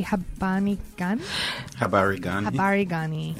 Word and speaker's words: Habari Gani: Habari Gani.